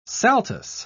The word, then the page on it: saltus: British English